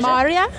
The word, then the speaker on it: Maarja: Maria. – Maria? – Yes. – You say, excellent. Maria. – So you spell it M-A-A-R-J-A, but you say it Maria.